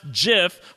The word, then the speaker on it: GIF: And she gave us our first great GIF of the season, and it is pronounced GIF when she was conga lining in the diary room.